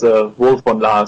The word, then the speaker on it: Wolf von Laer: I think you pronounced my name fine. It's Wolf von Laer, so perfect.